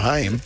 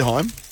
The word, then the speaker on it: HAIM: I just found out it's pronounced Heim, not Haim. – Oh, really? – So let's start there. – That's the band. – Is it really Heim? – Yes.